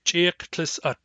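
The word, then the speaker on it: Cheklesahht: Starting in the north, the northernmost New Chanoth group is the Cheklasit tribe, which in East Barkly Sound dialect is pronounced Cheklisat. Cheklisat.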